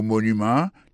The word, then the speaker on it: Monument: Si n'a mettez accent sur accent encore, on voit que mot Washington là, accent tombé sur premier syllabe là. Pour mot monument, il tombé sur premier syllabe là. The Washington Monument. Monument, c'est comme ça on dit monument en anglais.